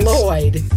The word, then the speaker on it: Lloyd: Put your tongue where you're going to about to say sugar and then instead blow out on the sides. – Say Lloyd.